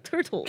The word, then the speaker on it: Turtles: Turtles. Turtle. turtles. Turtles. – It's also turtles. probably one of those words in British English where we might drop the T and it might become all turtles.